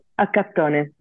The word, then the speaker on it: Accattone: is it Accattone? – Accattone, yes.